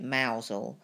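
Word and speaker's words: Mousehole: And I think that's based on like a myth, like a legend about a town in Cornwall called Mouse Hole. They actually pronounce it Mousel, but it's spelt Mouse Hole.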